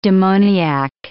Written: demoniac

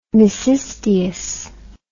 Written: mecisteus

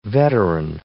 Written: veteran

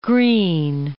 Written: Greene

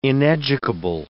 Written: ineducable

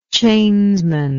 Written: chainsman

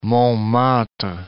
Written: Montmartre

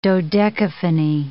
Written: dodecaphony

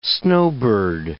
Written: snowbird

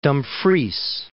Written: Dumfries

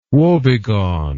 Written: wobegone

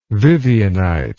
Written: vivianite

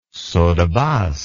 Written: saut de basque

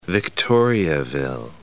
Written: Victoriaville

